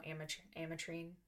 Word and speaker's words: Ametrine: And so for those of you that don't know, Ametrine.